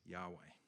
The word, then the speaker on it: Yahweh: Obviously, we write language differently now, but the best way that we know how to pronounce this name is Yahweh.